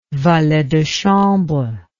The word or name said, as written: valet de chambre